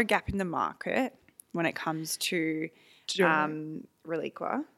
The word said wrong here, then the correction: Reliquia: Am I saying that properly? – Relicia. – Relicia.